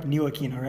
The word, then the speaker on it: Ntilikina: And there's no disrespect to Simmons, Mitchell, Frank Ntilikina. Which one is it again? Frank Ntilikina, right? It's on the N. Yeah, I think it's on the N. The T is silent.